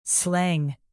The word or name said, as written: slang